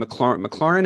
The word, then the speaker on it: McLaurin: They've got a wide receiver who I have my fantasy team, McLaurin.